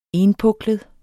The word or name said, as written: ENPUKLET